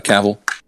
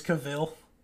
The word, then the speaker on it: Cavill: Who who did Henry uh, Cavill? – Oh, did people think it was Cavill? I don't. Well, uh, it rhymes with travel. That's what he said. So Henry Cavill.